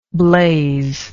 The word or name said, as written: blazeer